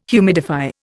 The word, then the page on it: humidify: Indian